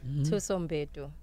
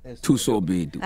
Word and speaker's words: Mbedu: Thuso Mbedu. – Thuso Mbedu.